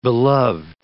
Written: beloved

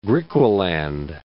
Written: Griqualand West

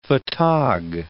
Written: photog

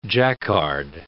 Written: jacquard

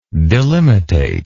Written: delimitate

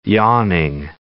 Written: yawning

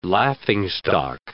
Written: laughingstock

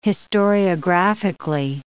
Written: historiographically

